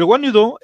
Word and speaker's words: Wanyudo: Eh, se escribe W-A-N-Y-U-D-O.